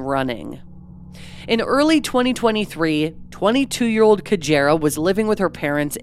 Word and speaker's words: Cajairah: Sadly, right down to the pronunciation of her name, although we know it's Cajairah because that's how her family says it in an interview, discrepancies and misinformation have plagued this strange case.